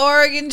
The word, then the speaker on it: Oregon: Oh, the Oregon Trail. – Was that a better pronunciation? – Yes.